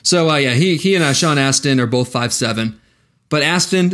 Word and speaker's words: Astin: So he and Sean Astin. It's not Sean Austin. You know, it's spelt Austin. – I think it's Astin. There's no U.